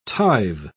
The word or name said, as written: tithe